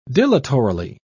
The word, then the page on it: dilatorily: British English